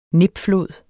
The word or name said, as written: nipflod